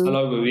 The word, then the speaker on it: Olowu: Uh, so Joseph Olowu was that, that how you say his name? Olowu. – Olowu, yeah.